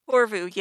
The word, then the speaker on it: Poorvu: She holds a PhD in religious studies from Yale University, where she also worked in their Poorvu. Am I pronouncing that right? – Poorvu, yeah.